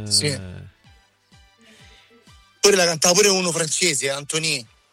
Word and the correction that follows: Antoine: Antoine. – Forse Antoine. – Antoine, scusa. Che pronuncia che c'ho? Antoine, sì. – Antoine, non Antonio.